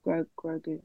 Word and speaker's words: Grogu: Grogu. That's how you say it, right? Grogu. – You got that right, at least. Yeah, you got the pronunciation.